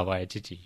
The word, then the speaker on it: Waititi: So next we have a racism video by, how do you pronounce his name? – It's Taiki Waititi? – Tahiki Waititi. – Ah, Waititi, yes.